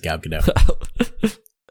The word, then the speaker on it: Gal Gadot: Is her name not pronounced Gal Gadot? Have I been being pretentious this whole time? Or no, it is Gal Gadot.